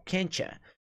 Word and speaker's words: can't you: Tá em can't, termina com T, e you. Can't. You can't. You soa como can't ya. Então, can't ya. Can't ya. Então, esse T mais U soa como cha.